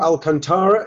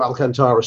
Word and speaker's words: Alcantara: Thiago Alcantara, is that how you say it, Ian Ryan? – Yeah.